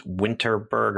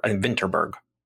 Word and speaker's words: Vinterberg: I think his last name is Winterberg or Vinterberg. Vinterberg. – And Benjamin. – Once again, I will lean hard into my Moss Mikkelsen fandom and try to pronounce it with a Danish accent and say this is Thomas Vinterberg. – Oh, that's much better than my pronunciation. – And as a filthy American, Thomas Vinterberg is correct.